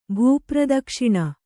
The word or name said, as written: ಭೂಪ್ರದಕ್ಷಿಣ